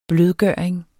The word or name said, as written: blødgøring